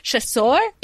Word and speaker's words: Chasseur: But then they have, you pronounce this. C-H-A-S-S-E-U-R-S. – Chasseur. – Chasseur. I was like, the chasers.